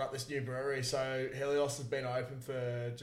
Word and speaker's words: Helios: Helios Brewing Co.